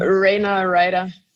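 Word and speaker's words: Rana Reider: Rana Rainer. Ich hoffe, ich habe ihn richtig ausgesprochen. – Rainer Ryder. Genau andersrum.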